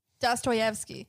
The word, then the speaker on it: Dostoevsky: How do you say his name? – Dostoevsky. – Dostoevsky? Wow. Yeah. Didn't know. It's not Dostoevsky.